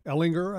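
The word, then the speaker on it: Ehlinger: They have three quarterbacks on their roster, and one of them's a young player, and Sam Ellinger, I think, is that how it's pronounced? – Yeah, from Texas, yep.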